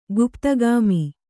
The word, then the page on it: ಗುಪ್ತಗಾಮಿ: guptagāmi